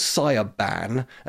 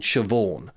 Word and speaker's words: Siobhan: One was called Sia Ban, and the other one was called Siobhan. Because I didn't know that you spell Siobhan Sioban.